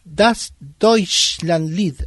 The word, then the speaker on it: Das Deutschlandlied: Bueno, vamos a hablar de Das Deutschlandlied.